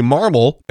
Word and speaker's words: Marmol: And of course, we have a new manager, Ali Marmol. And by the way, that is the correct pronunciation. There are some people saying Marmol, and that's incorrect. It's like marble. Just think of marble.